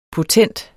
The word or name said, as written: potent